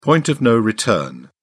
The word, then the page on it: point of no return: British English